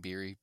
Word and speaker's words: Biery: That's spelled LUCAS, last name Biery, BIERY.